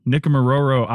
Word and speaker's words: Nikumaroro: They recently completed, as I said, their summer trip to, and you'll have to correct my pronunciation on this, but Nikumaroro Island, and we're going to talk about what they learned.